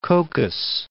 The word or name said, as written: Cocos Islands